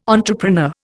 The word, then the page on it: entrepreneur: Indian